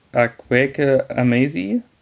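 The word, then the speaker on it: Akwaeke Emezi: genau, die Info, das Buch ist auf Deutsch erschienen, im Eichborn Verlag, Akweke Amazi, ich habe richtig ausgesprochen. – Genau.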